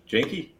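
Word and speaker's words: Jahnke: Yankee, am I saying that correctly? Um, Dan Yankee, right? – Janky. – Janky. Okay. All right.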